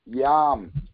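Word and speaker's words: Yom: If you write it out, it's Y-O-M. – Okay. – Okay. Yam. Yam. Like yam.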